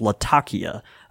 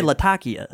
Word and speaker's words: Latakia: And it actually, most people over there are going to, refer to it more as Latakia. That's kind of the pronunciation. – That's the correct pronunciation? – I'm butchering it in my southern drawl, right, but yeah, they right. say Latakia. – Latakia.